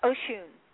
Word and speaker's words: Oshun: Well, I'm going to go ahead and, you know, close off our interview tonight with, how do you pronounce it, Oxun, O-X-U-N? – Oshun. – Oshun. Oh, that's Oshun.